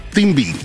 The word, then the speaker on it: cibi: and they pronounce it the Thimbi. It's spelled C-I-B-I, and it's very similar to what we see with the Haka.